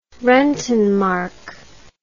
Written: rentenmark

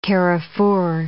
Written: carrefour

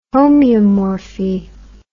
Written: homeomorphy

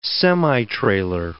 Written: semitrailer

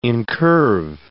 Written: incurve

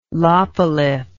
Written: lopolith

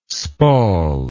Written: spall

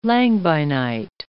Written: langbeinite

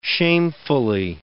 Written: shamefully

